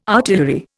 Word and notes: artillery: Indian

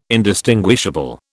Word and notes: indistinguishable: British English

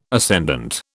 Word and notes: ascendant: British English

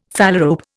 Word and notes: phalarope: Indian

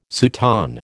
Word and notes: soutane: American English

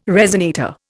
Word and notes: resonator: Indian

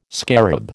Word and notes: scarab: American English

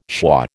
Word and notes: schwa: American English

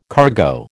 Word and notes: cargo: American English

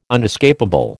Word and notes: unescapable: American English

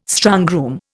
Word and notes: strongroom: Indian

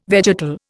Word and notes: vegetal: Indian

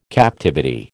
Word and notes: captivity: American English

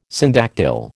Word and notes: syndactyl: American English

